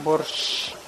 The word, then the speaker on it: borscht: Borscht. – Borscht.